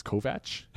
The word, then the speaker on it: Kovacs: It also taught me how to pronounce what I thought was Kovacs. Like it's Kovach? – Something like that.